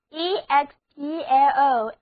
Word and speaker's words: explosion: 现在我们在 explode 后面把 de 换成 sion 的名词后缀，那么单词 explosion 字母组合 sion 读作人，那么单词 explosion explosion， explosion 就是爆炸、爆发。